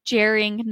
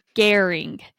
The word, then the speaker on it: Gering: So I was saying Gering, Nebraska last week and it's actually pronounced Gering.